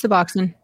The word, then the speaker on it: Suboxone: It's Suboxone.